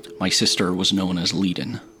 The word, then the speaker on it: Liadan: So, N-E-A is actually... – Go phonetically. – It's an older version of Enya, actually. And then Leiden is actually L-I-A-D-A-N... with an accent over the I. – This is her sister, who seems to be causing some problem, is Leiden.